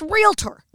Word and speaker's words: realtor: It's realtor. – Right. Right. – Look at how it's spelled. There's no A in the middle.